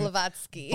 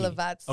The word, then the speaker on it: Blavatsky: Blavatsky. – Blah, blah, blah. – Blah, blah, blah. – Helena Blavatsky. – Blavatsky, okay.